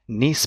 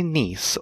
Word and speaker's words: Nice: Now, do you say niece or nice? Because it's spelt nice, NICE, but I've always said, and my family have always said, niece biscuit, and I don't trust people who say nice biscuit.